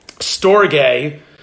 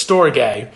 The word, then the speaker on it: storge: The next type of love that I want to talk to you about is storge love, or storge love. You can pronounce it, I guess, either way, but it's storge.